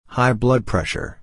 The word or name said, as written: high blood pressure